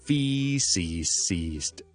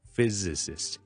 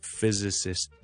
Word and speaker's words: physicist: physicist 就唔好读成 physicist 就唔得，系 physicist。第一个 s 咧系 z 嚟嘅，所以系比较难读啲嘅。听到先，physicist。